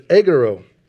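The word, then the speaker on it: egero (ἐγείρω): That word raises is the Greek word egero. Okay, I'm not a Greek scholar. I had to look that up on YouTube on how to pronounce that, so I didn't say it wrong. But it's egero. It means to raise, to bring to life.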